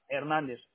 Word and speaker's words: Hernández: ¿Fernández con Z? – No, sí. – ¿Hernández? – Con H, Hernández.